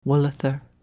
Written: Wullether

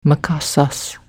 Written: mkasas

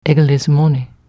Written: Englismoni

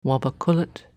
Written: Wabacullet

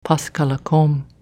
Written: Pascallacome

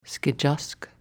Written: Skejask

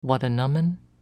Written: Wattanummon

